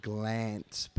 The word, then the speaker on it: Glanc: Well, it's it's it's a European name, and and the way you pronounce it in in in it's like a like a Yiddish German name, oh. and it means ah. – like and it's like glance, which which means to shine.